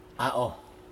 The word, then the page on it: ao: Aneityum